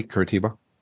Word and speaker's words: Curitiba: Did I say that correctly? Curitiba? – Correct.